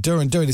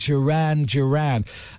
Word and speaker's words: Duran Duran: I said Duran Duran. It's actually pronounced Duran Duran.